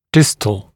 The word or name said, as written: distal